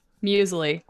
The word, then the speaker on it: muesli: Muesli.